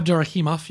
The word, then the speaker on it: Abdurakhimov: Yeah, Abdurakhimov, yep.